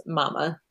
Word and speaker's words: Mamah: Her nickname was Mama. It's spelled M-A-M-A-H, which throws me off.